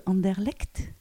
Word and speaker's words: Anderlecht: Dans la petite commune, tu vas peut-être m'aider à prononcer si c'est pas bon, de Anderlecht.